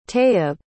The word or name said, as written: tayyib